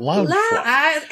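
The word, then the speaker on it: Loungefly: Is that how we pronounce it? – Lounge fly. Everybody in my circle says lounge fly.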